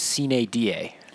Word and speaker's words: sine die: So everyone says sine die, but the Latin pronunciation is sine die.